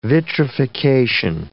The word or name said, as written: vitrification